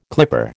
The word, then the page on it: clipper: American English